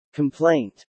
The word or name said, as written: complaint